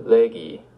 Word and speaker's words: legi: The stem becomes leg with a long E. So say now, I read a letter. Epistulum legi.